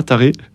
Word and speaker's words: Taré: Et voilà, en arrivant en revanche à mon lieu de mission, à Taré, c'est un nom, voilà, ça se prononce vraiment comme ça, Taré.